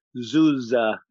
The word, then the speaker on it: Zuzzer: Yeah, we'll just say how they say, because it's spelled Z-U-Z-Z-E-R, but everybody, even the main actress, says Zuza. And I'm like, okay, it's Zuh. I don't care how it's written. That's how I'm saying it.